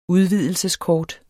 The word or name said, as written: udvidelseskort